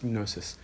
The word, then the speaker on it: gnosis: So on top of faith, on top of pistis, on top of virtue and being morally excellent, arete, comes knowledge, comes gnosis, or gnosis, sorry, I pronounced that wrong. The Greek is gnosis.